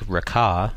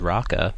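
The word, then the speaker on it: Raccah: Dominique Raca, which is how you pronounce it, I thought it was Raca,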